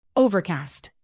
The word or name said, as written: overcast